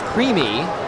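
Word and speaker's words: creemee: Also known as maple creams, pronounced creamy, maple creamy, in Vermont and even parts of New Hampshire, maple-flavored ice cream is a bit of a New England staple.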